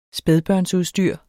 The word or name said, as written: spædbørnsudstyr